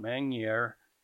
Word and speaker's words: Magnier: So Vince, he's owned by Michael Tabor, Derek Smith, and John Magnier. How do you pronounce Magnier. – His? Magnier. Okay, I should know that.